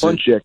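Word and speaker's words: pączek: One is P A C Z E K. – Okay. Pronounce Punchek. – It. Punchek. – And there's a little half a square under the A to make it an OM instead of an I.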